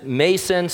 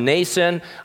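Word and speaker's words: Mnason: Some people pronounce it Mason, some Nason. I think the actual Greek is Nason.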